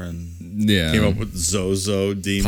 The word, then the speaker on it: Zoso: But there is a book in the 1600s, an occult book, that references a god of Saturn named Zozo, but it's spelled Z-O-S-O.